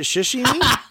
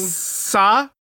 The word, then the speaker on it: sashimi: Is it shishimi? – Thank you. – Wait, he's... – Just... – I broke down the syllables for you. – I'm crying. – Sashimi. – Oh, sashimi. Okay.